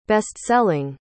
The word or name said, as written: bestselling